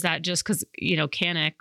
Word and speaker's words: Canik: So technically in Turkish, it's pronounced Canik.